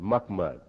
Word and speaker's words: Muhammad: But we pronounced it Muhammad. That's exactly the way we've told pronounce it, and that's how we pronounce it.